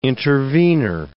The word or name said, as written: intervener